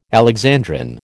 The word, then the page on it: alexandrine: American English